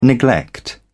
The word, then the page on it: neglect: British English